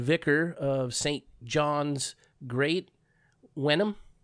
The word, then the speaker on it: Wenham: W-E-N-H-A-M. – In Wenham, yeah. Suffolk. Wenham, that sounds about right, yeah.